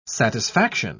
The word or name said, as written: satisfaction